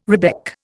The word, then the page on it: rebec: Indian